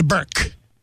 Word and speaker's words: Berk: And today, we head to Berk. That's one syllable, Berk. B-E-R-K, home of the Bulldog.